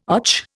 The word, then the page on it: och: Indian